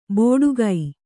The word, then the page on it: ಬೋಡುಗೈ: bōḍu gai